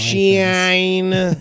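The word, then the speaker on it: Shyne: Shine. – That's how you say it.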